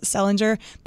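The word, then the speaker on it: St. Leger: which is spelled St. Ledger's Day. And in my research of this, I'm pretty sure you're actually supposed to pronounce it Sellinger, but my apologies if that is still not correct.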